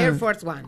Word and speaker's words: Air Force One: Al Air Force One. ¿Lo digo bien? – Air Force One.